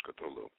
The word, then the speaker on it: Cthulhu: Now, Cthulhu was one of the, well, of course, there's several different ways to pronounce Cthulhu, but I think most everybody says Cthulhu.